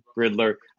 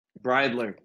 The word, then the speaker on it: Bridler: Bridler.